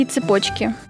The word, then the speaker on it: цепочка: Здесь опираться на логику «цепь», «цепочка» нельзя, а литературной нормой считается именно ударение в середине слова. Запомнить можно так. – Очень любят дочки, брошки и цепочки.